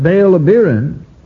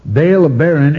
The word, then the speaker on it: Baal-Abirin: But Baal-Abirin, pronounced nearly the same way, Baal-Abirin, A-B-E-R-I-N, and Baal-Abirin, A-B-I-R-I-N, pronounced essentially the same way,